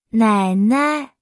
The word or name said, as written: 奶奶